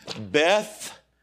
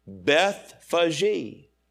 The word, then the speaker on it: Bethphage: I have to keep looking at it phonetically. It doesn't look like Bethphage to me.